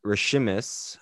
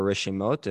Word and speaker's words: Reshimos: So today's piece of Torah, it comes from a book known as Rishimis, how a Chabad person pronounces it, or Rishimot, if you have a modern Israeli pronunciation.